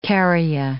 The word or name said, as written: kerria